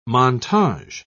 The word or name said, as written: montage